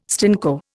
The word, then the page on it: stinko: Indian